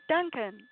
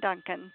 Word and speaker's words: Dunkin: It's Michael, M-I-C-H-A-E-L, and A-N-D, Diane, D-I-A-N-N-E, Duncan, D-U-N-K-I-N, Michael and Diane Duncan, just like the Dunkin' Donuts, that's how it's spelled.